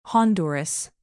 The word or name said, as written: honduras